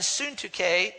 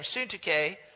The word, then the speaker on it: Syntyche: There was two ladies, Euodia and Syntyche, is how you say her name, Syntyche.